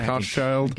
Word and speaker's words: Hochschild: It changed like halfway through the season. It was like Hochschild. – Like idiots like us were just saying it wrong.